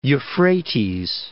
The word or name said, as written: Euphrates